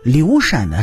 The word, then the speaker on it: 刘禅: 但是刘禅的“ 禅” 只能读成“ 禅”，因为这佛教是在东汉中叶才从印度传入中国的，在刘禅所在的时代。